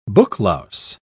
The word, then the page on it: booklouse: British English